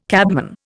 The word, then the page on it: cabman: Indian